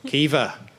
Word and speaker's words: Caoimhe: So the woman's called C-A-O-I-M-H-E. Kiva. Well done. Someone got Kiva right. And her brother is called, I'll be impressed if you get this, C-O-N-C-H-O. B-H-A-R.